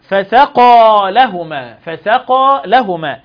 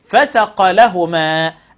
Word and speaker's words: فسقى: وقد يؤدي سقوط حرف المد وعدم مده إلى فساد المعنى تماما، مثل فسقى لهما، فإذا لم نمد حرف المد حركتين تنطق فسق لهما، أي من الفسق، وهذا فساد للمعنى. يبقى أنا لو مدتش وقلت فسقى، لو ما مدتش القاف ومدتش الألف الحركتين يبقى المد يسقط وتبقى فسق، يبقى هنا من الفسق والمعنى يتغير.